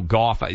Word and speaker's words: Gauff: And so it doesn't honestly surprise me that Coco Gauff is how you pronounce her name.